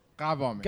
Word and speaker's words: Gavami: Men hur uttalas det? – Gavami. – Okej, okay. Ja, nästan rätt då.